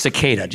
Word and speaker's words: cicada: It's pronounced cicada, so you know.